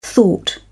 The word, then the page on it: thought: British English